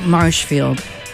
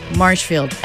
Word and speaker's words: Marshfield: That is actually out in Mashfield, Massachusetts. – Mashfield? – Mashfield. Well, Marshfield. – Marshfield? – Marshfield, as in marshy, as in marshes.